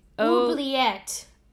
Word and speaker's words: oubliette: I don't know if I'm pronouncing that correctly. – Oubliette. – The L was in the wrong spot for me. It's oubliette. You're correct.